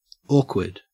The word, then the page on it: awkward: Australian English